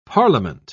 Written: parliament

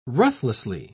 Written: ruthlessly

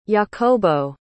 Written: jacobo

